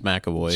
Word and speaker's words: McAvoy: And McAvoy, is that how you say his name? – James McAvoy.